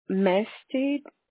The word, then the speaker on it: Mestead: Mestid, M-E-S. – Can you spell that? – Yes, M-E-S-T-E-A-D.